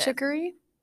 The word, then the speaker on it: chicory: And then dandelions, and is it, how do you pronounce it? – Chicory. – Chicory root.